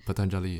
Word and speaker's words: Patanjali: Patanjali.